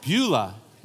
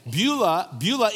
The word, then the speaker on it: Beulah: Beulah. Beulah is the pronunciation in Hebrew.